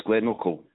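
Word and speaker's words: Glenochil: I can see that you were in Glenochill, yeah. – Have I said that right? – Yes, Glenochill.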